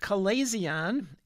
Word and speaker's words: chalazion: The next C condition is pronounced chalazion. I'll spell it for you. It's C-H-A-L-A-Z-I-O-N, a chalazion.